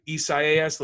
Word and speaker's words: Isaias: Isaias, let's all pronounce that together, Isaias.